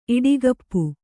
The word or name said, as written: ಇಡಿಗಪ್ಪು